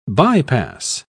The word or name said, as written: bypass